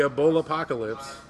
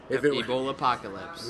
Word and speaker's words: Ebola apocalypse: Okay, so if the Ebola apocalypse, did I say it right? – Yeah, you got it. – Ebola apocalypse.